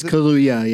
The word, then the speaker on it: Kaluuya: It's Kaluuya, yeah.